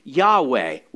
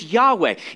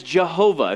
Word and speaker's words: Yahweh: And that name is Yahweh, Y-H-W-H. We threw in a couple vowels there so we would know how to pronounce it. It's Yahweh. In Germanic languages, they pronounce it as Jehovah, if you've ever heard that. Same word, just slightly different pronunciation on the letters there.